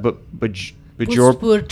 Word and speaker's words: Bhojpur: Bhojpur trail race also I started to do in my village town.